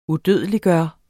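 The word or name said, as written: UDØDELIGGJORTE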